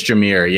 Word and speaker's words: Jahmyr: I think it's Jahmyr. Yeah. I've not Jahmyr, heard any contrary pronunciation. So that's what I'm rocking with until someone tells me to stop. – Yeah. Jahmyr Gibbs and then CJ Verdell.